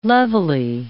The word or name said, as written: levelly